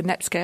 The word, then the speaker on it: netsuke: And if you could give us the bare bones of that story, explain the role of the linking thread of the Netsuke.